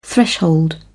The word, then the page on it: threshold: British English